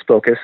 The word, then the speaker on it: SPOCUS: And I mean S-P-O-C-U-S, SPOCUS. Is that how you say it? – Right. It's pronounced as SPOCUS, so the okay.